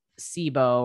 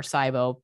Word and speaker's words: SIBO: So a lot of bodybuilding clients that I get have SIBO or SIBO, people pronounce it different ways, but it's small intestinal bacterial overgrowth.